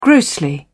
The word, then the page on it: grossly: British English